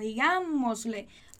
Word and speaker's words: digámosle: Porque quando a gente tem uma palavrinha que ela tem 3 ou mais sílabas nessa palavra, nesse caso a gente tem 4, né? Digámosle. E a ênfase da palavra, ela não tá nem na última sílaba, nem na penúltima, mas tá lá naquela antepenúltima sílaba, né?